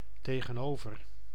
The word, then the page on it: över: ˈøːv.ɛr